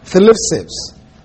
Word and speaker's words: thlipsis: Thlipsis. It's T-H-L-I-P-S-I-S. Thlipsis. – Spell it the other way. – C-H-L-I-P-S-I-S. Thlipsis. Thlipsis literally means pressing together.